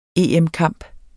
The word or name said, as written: EM-kamp